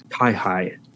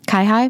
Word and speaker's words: Kaihoi: Is that how you pronounce his last name? – Kaihoi. – Kai High. – Mm-hmm.